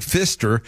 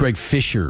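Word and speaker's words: Fischer: I will say that one time during one of his addresses, Greg Pfister said that It's Fisher. You think you're pronouncing it wrong. – Pfister? – It's Greg Fisher. – Greg Pfister. – There's no T.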